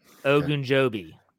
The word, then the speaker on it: Ogunjobi: And Ogunjobi. – Mm-hmm. – Did I say that correctly? – Yes, yeah. You finally got it right.